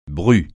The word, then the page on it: bru: francais